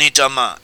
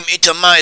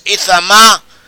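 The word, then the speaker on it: Itamar (איתמר): Eleazar was in charge of this work of the clan of Kahat, whereas Itamar is how the name is pronounced in modern Hebrew. In the Bible, it is, or in our biblical translation, this name Itamar is given as Ithamar, which is probably closer to how the original Hebrew was pronounced.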